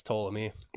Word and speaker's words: Ptolemy: So she was actually a descendant of Alexander the Great's Macedonian general, Ptolemy. – I think it's Ptolemy. Mm. The P. – A silent P. – Like pterodactyl.